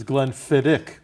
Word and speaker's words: Glenfiddich: So we were schmoozing before, our viewers, that the right way to pronounce it is Glenfiddich. – It's a Gaelic pronunciation.